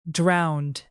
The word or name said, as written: drowned